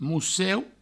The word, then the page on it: mosseu: mousseu